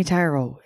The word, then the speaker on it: tarot: No, it is definitely tarot, isn't it? – It's definitely tarot, yeah.